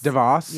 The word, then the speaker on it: DeVos: Fucking, how do you pronounce her last name? Betsy Davos? DeVos? – DeVos. – Yeah.